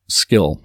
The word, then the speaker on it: Skil: It's called Skill. It's Skillsaw. It's spelled S-K-I-L.